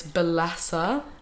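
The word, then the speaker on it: Bellesa: But yeah, I definitely think masturbate, get yourself a nice vibrator, make the room all nice, and have a look at, what is it, Belisa? – But it's, yeah, it's Belessa. – How do you spell that? – B-E-L-L-E-S-A, I believe.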